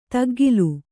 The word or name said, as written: ತುಗ್ಗಿಲ್